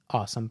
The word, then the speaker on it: OSOM: You can also follow along on the social medias at OSOM Podcast, pronounced Awesome Podcast, on Instagram, Twitter, and Facebook.